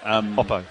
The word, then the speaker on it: Oppo: Oppo.